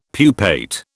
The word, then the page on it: pupate: British English